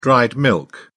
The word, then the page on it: dried milk: British English